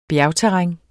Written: bjergterræn

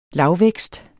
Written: lavvækst

